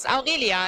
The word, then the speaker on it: Aurelia: It's Aurelia. It's pretty hard to pronounce in English because it's Italian.